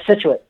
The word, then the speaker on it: Scituate: Yeah, Scituate.